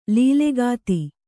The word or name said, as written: ಲಲ್ಲೆಗಾತಿ